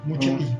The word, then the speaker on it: Muschietti: Ya se mencionaba que en su lugar podría estar ocupado por Andy Muschietti, perdón por la... – Muschietti. – Gracias, porque no lo pronunciaba bien.